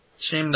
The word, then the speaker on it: Shyam: I mean, I'll look forward to hearing more about it, or kind of find out why, or read more about Shane Doss, or however you say it, Shane Doss. – Shame Doss. – Shame Doss. – Spelt S-H-Y-A-M, but pronounced Shame.